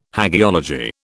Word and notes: hagiology: British English

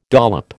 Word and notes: dollop: American English

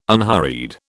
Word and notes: unhurried: British English

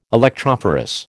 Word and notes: electrophorus: American English

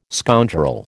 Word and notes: scoundrel: American English

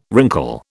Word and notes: wrinkle: British English

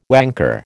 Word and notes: wanker: American English

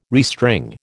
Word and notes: restring: American English

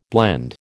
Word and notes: blende: American English

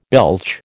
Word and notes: belch: American English

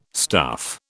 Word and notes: stuff: British English

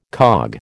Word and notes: cog: American English

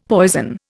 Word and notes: poison: Indian

